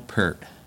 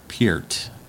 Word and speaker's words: Peart: I think it was Peart, maybe. I don't know. – Depends on where you're from. If you're Canadian, you can pronounce it correctly every time. Everyone else, everywhere else, struggle with it.